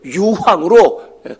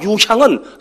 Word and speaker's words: 유향: 늘 말씀드리는 겁니다만 이 유향을 유황으로 잘못 발음하시면 안 됩니다. 유황이 아니에요. 유황과 유향은 성경에서 정반대 의미입니다.